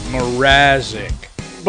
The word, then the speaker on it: Mrazek: Mrazek.